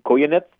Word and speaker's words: Koyanets: Oleg Koyanets? – Exactly. – Yeah. Koyanets. That's how you pronounce it. I actually had to request a phonetical pronunciation on that.